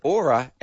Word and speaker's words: Oura: Written, Yeah, but it's spelled O-U-R-A, but the wonderful people who have written the ad have said aura as in flora, just in case I don't know how to pronounce it.